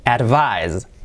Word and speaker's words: advise: Fijaos que la pronunciación cambia un poquito, es casi inapreciable, pero cambia un poquito porque la S final es z, la S sonora, así, z, activando las cuerdas vocales. Advise, así, z, advise.